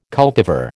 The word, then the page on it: cultivar: American English